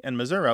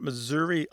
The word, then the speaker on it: Missouri: But what we really see here, it's not Missouri alone that is sometimes pronounced with that E becoming an uh. There are other words that fall into this.